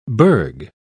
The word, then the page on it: burg: British English